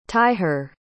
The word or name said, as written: tyhir